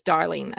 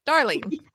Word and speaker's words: Darling: But so my name is spelled D-I-E-N-E-C-E. And then it's darling, as in sweetheart.